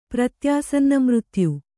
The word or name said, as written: ಪ್ರತ್ಯಾಸನ್ನಮೃತ್ಯು